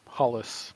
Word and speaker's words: Hollis: Is that how you say it? – Yeah, Hollis.